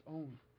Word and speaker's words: On: That article, or Joseph and On, perhaps it's called, it's pronounced On. It's a city in Egypt called On.